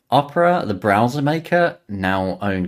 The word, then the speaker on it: Opera: is it Opera or Opera? The browser maker. – Yeah, Opera. – Opera, yeah. Okay, so they were acquired by Opera Software earlier this year.